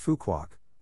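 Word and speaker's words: Phú Quốc: The nature on Phu Quoc, pronounced Phu Quoc, was flourishing so richly that UNESCO designated the island a biosphere reserve in 2006.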